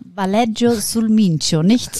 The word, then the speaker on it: Valeggio sul Mincio: Valeggio sul Mincio, nicht sulla Minchia, sondern sul Mincio.